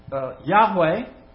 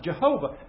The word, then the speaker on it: Yahweh: And is sometimes pronounced Yahweh and sometimes Jehovah. And the reason that we're not sure is because it's only four letters and they're consonants. J-H-V-H, sometimes Y-H-V-H. And they added the vowels.